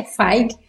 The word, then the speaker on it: Feige: E, e isso o Kevin, inclusive Kevin, eu falava Kevin Feige, e descobri recentemente que é Feig. Ainda não estou acostumado com essa pronúncia.